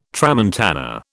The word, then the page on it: tramontana: British English